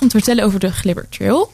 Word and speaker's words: Glibbertreel: Wat er in de regio zeker ook gaat gebeuren, dat is de Glibber Trail. Of eh, ja, je kan dat bijna als leidenaar niet zo uitspreken. Het is natuurlijk de Glibber Trail.